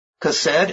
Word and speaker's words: chesed: And in Strong's, it's spelled C-H-E-S-E-D. Pronounce chesed, chesed, with the emphasis on the first syllable. That first syllable is spelled K-H-E-H. And it's a deep guttural sound. In other writings, we found it spelled H-E-S-E-D.